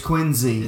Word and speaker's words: Quincy: I moved to Quincy, and it took me a while to figure out that it's Quincy. – It's Z, with a Z. Quincy, Illinois, is a place, and Quincy, Massachusetts is a different place.